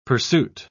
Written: pursuit

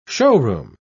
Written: showroom

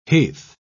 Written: heath

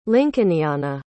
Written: lincolniana

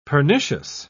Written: pernicious

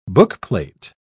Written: bookplate

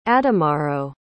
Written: ademaro

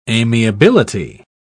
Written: amiability

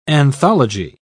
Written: anthology

